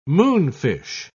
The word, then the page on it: moonfish: British English